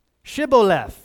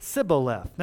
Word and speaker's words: Shibboleth: And if you wanted to cross, you had to say what? Shibboleth, which means flowing river. Well, the Ephraimites pronounced it Sibboleth.